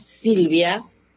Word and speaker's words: Silvia: porque mi nona no podía pronunciar bien Silvia en español, como es Silvia.